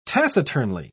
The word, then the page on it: taciturnly: British English